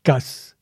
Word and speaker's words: chaos: Au XVIIe siècle, ce mot se disait gaz, mot inventé par Van Helmont, médecin chimiste flamand, à partir du mot latin chaos, qui devait se prononcer casse. On ne prononçait pas le O de chaos.